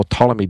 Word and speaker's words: Ptolemy: The one in the south was Ptolemy, and he kind of established the Ptolemy dynasty, or Ptolemy dynasty, depending how you pronounce it.